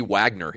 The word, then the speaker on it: Wagner: I think we pronounce his name like we would pronounce his name in America. If his people had immigrated through Ellis Island, he would be Wagner here. There are tons of people with the last name, probably listening to us right now, who have the last name Wagner. That's how we pronounce it here.